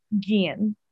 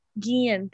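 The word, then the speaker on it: Guillén: Guillen. – Guillen, thank you.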